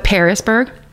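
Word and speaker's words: Pearisburg: but it's like the fruit pear, not Paris, Paris, France, but Parisburg.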